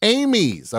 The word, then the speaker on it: Amies: You said it perfectly the first time, Tom. Amy's. – Amy's.